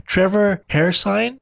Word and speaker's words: Trevor Hairsine: But the artist for this book is, I want to make sure I get his name right, Trevor Hairsine, which is funny because there's no sign of his hair.